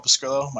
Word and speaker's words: Pescrillo: All right, well, you can find me at Paul Pescrillo. My last name is spelled PESCRILLO on Twitter.